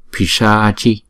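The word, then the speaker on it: Piśācī: Chữ A là đọc hai nhịp Pisaachi, Parnasavari, Parna.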